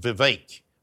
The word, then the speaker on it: Vivek: Now, I'm having watched a couple of US debates, I'm reliably informed that Vivek Ramaswamy's name is pronounced Vivek. But I don't know if that's just American. But they say it rhymes with cake.